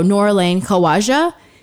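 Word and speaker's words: Noorulain Khawaja: So Nora Lane Khawaja.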